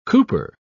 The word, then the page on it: cooper: British English